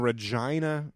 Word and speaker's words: Regina: Oh, so we performed in the show that we did in Regina. Rhymes with vagina, Canada.